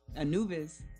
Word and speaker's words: Anubis: It's called Anubis? Anubis. Thank you. That's how you pronounce it.